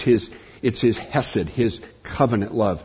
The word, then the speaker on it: hesed: This is hesed.